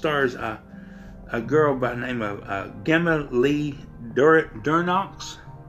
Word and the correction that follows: Devereaux: No, DeVero. Excuse me, I'm sorry, I'm pronouncing this last name wrong. But it's Devereaux.